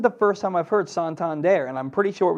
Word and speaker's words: Santander: He asked the guy, and he was like, it's Santander.